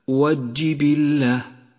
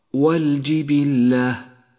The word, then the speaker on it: والجبلة: لام هذه الكلمة لام قمرية تكتب وتلفظ وكثيرا ما تقرأ خطأ هكذا والجبلة فتنبه لأنه خطأ شائع الصواب أن تكتب اللام وتلفظها والجبلة اقرأ